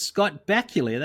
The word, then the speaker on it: Bakula: It was rhymes with not Dracula. It's Scott Bakula.